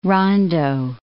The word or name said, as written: rondeau